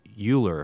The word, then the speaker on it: Euler: Which, yeah, it's actually E-U-L-E-R. Many people pronounce it Euler if they've never, but it's Euler.